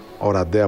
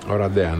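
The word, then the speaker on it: Oradea: Ρουμανία-Ελλάδα στην πόλη Οραντέα. Οραντέα, όπως προφέρεται. – Οραντέα, ναι.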